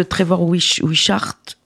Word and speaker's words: Trevor Wishart: Est-ce que tu peux nous parler de Trevor Wishart, si je le prononce bien? – Oui, tout à fait bien.